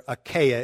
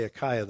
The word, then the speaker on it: Achaia: So, verse 15, now I urge you, brothers, you know that the household of Stephanas were the first converts in Achaia, or Achaia is actually how it's pronounced. I always want to say Achaia, though.